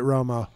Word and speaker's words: Romo: It's Roma. – Better. – Roma. – Roma. – Well, he pronounces it Romo.